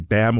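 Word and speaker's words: BAMMJ: It's called the BAM Award. That's B-A-M-M-J. The J is silent.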